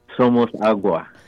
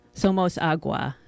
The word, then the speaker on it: Somos Agua: That was Matik Matik from the 2014 CD Somos Agua. Now, Tony, I'm totally mangling that pronunciation probably. – Somos Agua. – Somos Agua. Thank you.